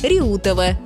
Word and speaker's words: Реутова: Правильно, город Реутов с ударением на «е» и железнодорожная станция Реутова с ударением на «у».